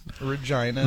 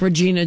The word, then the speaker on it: Regina: R-E-G-I-N-A. – Yeah, Regina. – They don't even pronounce it Regina. – No. – Like Regina George from Mean Girls. It's actually pronounced Regina.